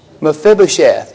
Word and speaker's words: Mephibosheth: It's a four-syllable name. Mephibosheth, yeah, if Mephibosheth has a health problem, what is it?